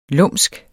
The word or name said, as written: lumske